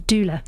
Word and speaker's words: doula: Did I get the pronunciation right? Doula. – Doula. Yes, it's a doula.